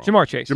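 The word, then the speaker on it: Ja'Marr Chase: Jamar Chase.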